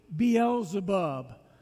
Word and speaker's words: Beelzebub: And so the word that we have, Beelzebub, is a transliteration from the Greek. It is literally how you would pronounce the word in the Greek, Beelzebub.